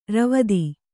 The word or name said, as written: ರಾವ್ತ